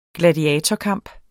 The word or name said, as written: gladiatorkamp